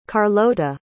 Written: carlota

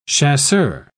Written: chasseur

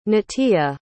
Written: natia

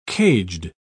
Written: caged

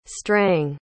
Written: strang

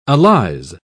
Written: allies

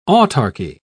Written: autarchy

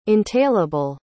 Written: entailable

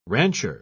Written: rancher

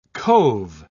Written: cove

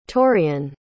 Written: torean

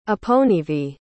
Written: aponivi

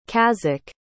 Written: kazakh

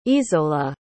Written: izola

